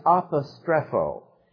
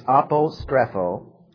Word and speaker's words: apostrepho: The Greek word for distort is apostrepho. It means to reverse. It does not mean to distort, by the way. It means, and the word in the Greek is apostrepho. Apostrepho. Spell it A-P-O-S-T-R-E-P-H-O.